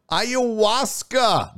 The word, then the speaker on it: Ayahuasca: How to pronounce Ayahuasca. – Did you guys hear that? – Ayahuasca. – I wasn't even close.